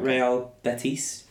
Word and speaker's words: Real Betis: Real Betis. – Ah, okay. No one going to Spain. – How do you say that? – Yeah, that's pretty much right. Real Betis, yeah.